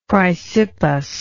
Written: chrysippus

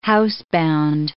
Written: housebound

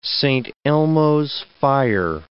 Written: Saint Elmo's fire

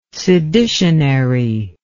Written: seditionary

